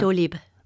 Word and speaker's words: Doctolib: So did I say it right that Doctolib. – Time? – Doctolib.